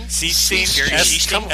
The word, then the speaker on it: Sichting: Or we could add Jerry, I say his name wrong, Sitchi. – Schiesting? – Schiesting. S-I-C-H-T-I-N-G.